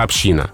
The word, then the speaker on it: община: Ну, смотрите, эти варианты в русском языке конкурируют. И если вы на грамоте посмотрите в словарях, адресованных работникам эфира, то есть, в общем, нам с вами, там вариант только «община». – Угу. – И лишь в устойчивом выражении «палата общин» в английском парламенте там ударение на первом слоге.